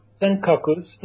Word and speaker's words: Senkakus: Senkakus.